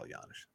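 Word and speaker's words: Janish: with the possible exception of Paul Yanish. Paul Yanish was close. No, I just wanted to say Paul Yanish, soft J.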